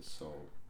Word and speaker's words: Seoul: Seoul.